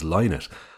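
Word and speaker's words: Lynott: And I used to notice that you, Mick, always pronounced it as Linnet. And it used to kind of wind me up because I thought you were wrong.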